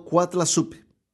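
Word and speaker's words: Coatlaxopeuh: Se cree que Nuestra Señora usó el término azteca náhuatl de Cuatlasopio, el cual es pronunciado Cuatlazupi y suena extremadamente parecido a la palabra en español Guadalupe.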